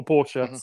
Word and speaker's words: Porsche: which sounds a little bit, or Porsche, sorry. Let's get my German pronunciation right.